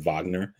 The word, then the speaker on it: Wagner: It's spelled Wagner, but they pronounce it Wagner.